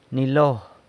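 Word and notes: nilo: San Lucas Quiaviní Zapotec

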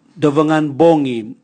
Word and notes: dovongan bongi: Raga

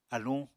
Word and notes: aluñ: apatani

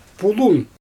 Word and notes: pulun: Xyzyl

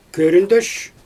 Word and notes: köründösh: Xyzyl

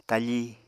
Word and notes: tafí: Apatani

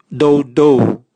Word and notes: doudou: Raga

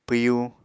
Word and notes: pwyu: Apatani

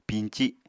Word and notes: piñci: Apatani

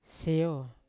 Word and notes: seo: Yawa

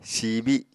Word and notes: siibi: Apatani

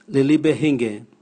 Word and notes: lilibe: Raga

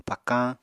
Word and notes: pakañ: Apatani

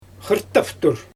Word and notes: xɯrtaftur: Xyzyl

